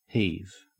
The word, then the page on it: heave: Australian English